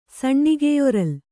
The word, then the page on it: ಸಣ್ಣಿಗೆಯೊರಲ್: kannada